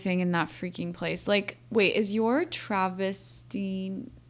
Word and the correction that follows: travertine: is that how you say it, coffee table, is it from them? Travertine.